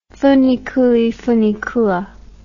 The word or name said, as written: funiculi funicula